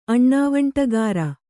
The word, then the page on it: ಅಣ್ಣವಂಟಿಗಾರ: kannada